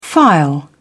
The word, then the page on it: file: British English